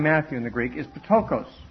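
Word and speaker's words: Ptokos (πτωχός): And I was told by one Greek teacher that you always pronounce the P and the T. And others say you don't do that. So no one speaks ancient Greek anymore, so no one really knows how it's supposed to be pronounced. But the way I was taught, you'd pronounce it Ptokos.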